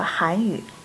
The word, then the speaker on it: 韩语: 看来啊，不仅汉语和韩语这两个词的发音很像，这两种语言其实也真的有相似的地方。 – 是啊，我刚学汉语的时候就觉得很有趣。汉语的“ 汉” 是四声，韩语的“ 韩” 是二声，这两个词的发音很像。